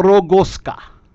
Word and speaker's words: Rogowska: The W is pronounced as a V. – Is it Veronica? – Yeah. – Veronica Rogowska.